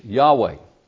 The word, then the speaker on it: Yahweh: Or YHWH, Yahweh, we pronounce it.